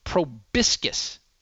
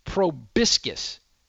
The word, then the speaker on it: proboscis: The proper pronunciation, I believe, is proboscis. Proboscis.